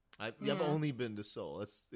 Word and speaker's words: Seoul: How do you say that? – Seoul. – Seoul? Seoul? – Yeah, Seoul.